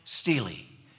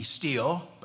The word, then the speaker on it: stele: Now, there are those that pronounce that word, S-T-E-L-E, steel, but I learned today that it's not pronounced steel, even though it's accepted in some circles, but it's supposed to be pronounced Stele.